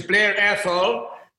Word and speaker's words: Blair Athol: Am I saying that right? – Yes, it was Blair Athol.